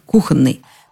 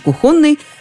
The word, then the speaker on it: кухонный: В русском языке это звучит без вариантов, только так, кухонный. Нормативные словари в курсе того, что иногда произносят иначе, кухонный, но они резко возражают против такого ударения.